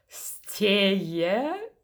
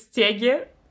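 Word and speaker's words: Stege: как мы узнали, называется Стее, ну, то есть Стеге по-русски, mm-hmm. – потому что там есть буква Г, которая не произносится.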